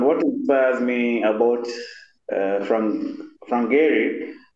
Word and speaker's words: Gehry: Gehry, am I saying that right? – Yeah, sure. Gehry.